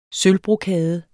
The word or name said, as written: sølvbrokade